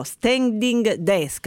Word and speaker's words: standing desk: Ne parleremo, ma dottoressa Parolo, nell'attesa che prenda piede la moda americana che promuove lo standing desk, l'ho detto bene, Deborah? – L'hai detto benissimo.